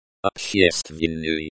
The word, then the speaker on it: Общественный: Общественный. – Stress on the second syllable. Ob rhymes with orb. She rhymes with she. STV sounds like the scent in street, and N rhymes with N.